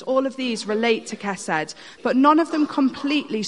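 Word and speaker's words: chesed (חסד): It's spelt H-E-S-E-D if you translate it into English, but it's pronounced chesed.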